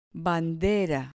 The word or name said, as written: bandera